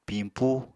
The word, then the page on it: pimpú: Apatani